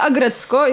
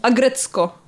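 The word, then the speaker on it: Aggretsuko: Nazywa się Agrecko i jest to serial animowany japoński. – Jeszcze raz e, tytuł? E, Agrecko. Okej. Okay. Pisze się Agretsuko.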